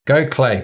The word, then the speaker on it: Gokhale: Go Clay, pronounced, spelt Gok Hale, as we would say here in Liverpool.